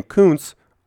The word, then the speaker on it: Kuntz: We need to be sure to pronounce his name Kuntz, other than the other way you could say it because that might be bad.